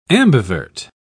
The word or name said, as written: ambivert